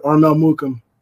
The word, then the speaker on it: Armel Mukam: Armel Mukam.